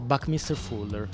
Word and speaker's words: Buckminster Fuller: ma ha detto benissimo Buckminster Fuller, che non è una cosa da poco.